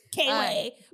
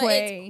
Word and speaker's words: Quaylon: That makes so much sense because his name is Quaylon Rogers. So I was fucking up his name the whole time. I was really butchering that shit. I was like, damn. I know that must have been like, you know. – Painful. – So, yeah, for the ears to hear. K-Way. But Quay.